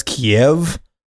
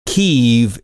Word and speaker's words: Kyiv: And if you're like me, though, you always probably heard Kyiv pronounced as Kiev. That's the Russian pronunciation. Kyiv is how the locals, Ukrainians, pronounce it. So that's why you hear so many people shifting that pronunciation to Kyiv as opposed to Kiev.